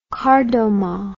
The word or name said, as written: kardomah